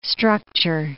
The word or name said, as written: structure